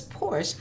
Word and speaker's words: Porsche: Porsche. – Yes. – Okay. – It's Porsche. A two-syllable word.